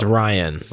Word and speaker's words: Rian: I think it's Ryan. But it's spelt with an I.